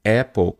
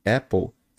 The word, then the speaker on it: apple: Então você que dizia é apple, apple com a boca mais fechada, apple, estava pronunciando errado. Na verdade, nós temos que falar assim, ó, apple, apple. Abro bem a boca, não é apple, apple. Não é o é de do verbo ser e estar do português, né?